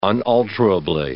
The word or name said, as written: unalterably